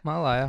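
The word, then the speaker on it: Ma'alaea: So anywho, great new venue at a harbor that I mispronounce all the time called Mai'alaya. Is that how you pronounce it? – Yeah. – Mai'alaya Harbor.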